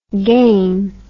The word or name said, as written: gaine